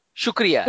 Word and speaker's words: Shukriya: Shukriya, Shukriya.